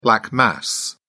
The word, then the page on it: black mass: British English